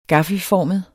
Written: gaffelformet

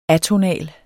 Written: atonal

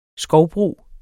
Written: skovbrug

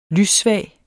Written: lyssvag

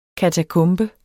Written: katakombe

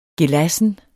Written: gelassen

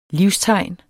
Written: livstegn

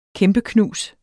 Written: kæmpeknus

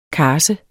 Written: karse

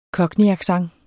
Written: Cockney

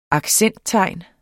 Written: accenttegn

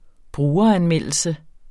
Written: brugeranmeldelse

